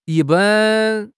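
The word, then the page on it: yi1 ban1: tone 1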